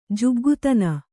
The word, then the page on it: ಜುಗ್ಗುತನ: kannada